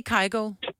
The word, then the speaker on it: Kygo: Men i Norge, der siger I Kygo? – Ja, det gør vi. – Okay. – Jeg tror, vi udtaler det ligesom, du ved, engelskmænd eller amerikaner eller sådan noget.